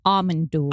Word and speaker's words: Amon Düül: Actually, the correct pronunciation is Amon Düül.